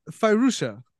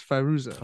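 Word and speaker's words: Fairuza: I got Farusha. Balk. – There we go. – That's how I'm gonna guess. Farusha.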